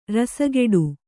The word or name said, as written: ರಸಗೆಡು